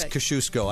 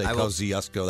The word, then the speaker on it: Kosciuszko: But I think it is Kosciuszko. I think it's also acceptable to say Kosciuszko. That's what most people say.